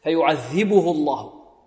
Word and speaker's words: فيعذبه الله: فيعذبه الله هكذا، إذا وقفنا عليه نسكي فيعذبه اضطرارا واختبارا، لكن في الوسط تحرك الهاء بالضم ولا توصل بواو لفظية، والهاء مرققة، فيعذبه الله، واللام في لفظ الجلالة ماذا؟